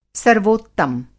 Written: सर्वोत्तम

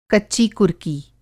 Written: कच्ची कुर्की

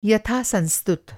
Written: यथासंस्तुत

